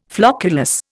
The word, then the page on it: flocculus: Indian